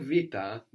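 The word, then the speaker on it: vita (βήτα): So we said vita, the vita, the letter that looks like a B is pronounced like a V sound in Greek, like in the word book, which is Vivlio.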